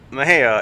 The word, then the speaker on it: Mexia: And one of the guys asks the others, "Hey, how do you say that name?" Uh, it's like, "I think it's Mejia." It's like, "No, no, look at it. It must be Mexia."